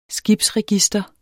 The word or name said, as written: skibsregister